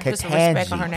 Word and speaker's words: Ketanji: Ketanji.